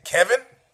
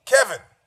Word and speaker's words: Kelvin: Kelvin, you might say Kevin? No, Clevin? No, Kelvin? Kelvin.